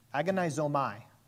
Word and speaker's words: agonizomai: The Greek word for strive, which I'm going to get wrong and butcher the pronunciation of, is agonizomia. Agonizomai. We're going to go with that.